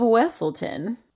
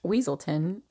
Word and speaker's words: Weselton: Who also voiced the Duke of Weselton, often mispronounced Weaselton, in Frozen.